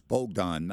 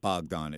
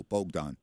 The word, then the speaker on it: Bogdan: And it's and listen, I made this same mistake, so I'm not saying this, but it's Bogdan, not Bogdan. It's Bo, like B-O-W, so like it's a long O, Bogdan.